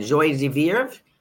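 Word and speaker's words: joie de vivre: How do you say that? Joy de vivre? De vivre? Good.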